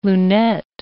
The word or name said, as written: lunette